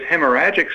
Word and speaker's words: hemorrhagic: I did have a text this morning that asked about, could you talk about hemorrhagic or strokes? Did I say that right? I know I didn't. – You did. Hemorrhagic.